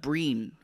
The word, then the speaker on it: Brian: Breen, I think is how you say it. They're I think Irish, but it's Brian, spelled like Brian, but I think you pronounce it as Breen.